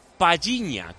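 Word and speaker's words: Palhinha: El portugués Paliña, hay varios oyentes, algunos desde Portugal, que nos dicen que se pronuncia Paliña, como si fuera una doble L ahí, Paliña.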